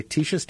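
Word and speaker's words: Teeshia's: It's called Tisha's Taste Cafe. And it's spelled T-E-E-S-H-I-A. Tisha's Taste Cafe.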